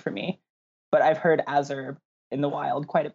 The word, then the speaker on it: Azure: You just answered my first question, which is, what's the proper way to say Azure? – I say Azure because I'm really into like mineral collecting, and my favorite mineral is Azurite.